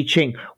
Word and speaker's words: I Ching: The I Ching.